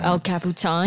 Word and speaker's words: El Capitan: El Capitan.